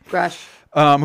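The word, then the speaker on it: Grusch: So this is all off of we're doing this episode because David Grush, is that how you pronounce his name? – Yep. – David Grush? Grush. – Grush.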